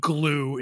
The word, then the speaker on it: Glew: And it's about this guy named Steve Glue. It's spelled G-L-E-W, but it's pronounced, you know, Glue.